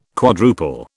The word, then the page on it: quadruple: British English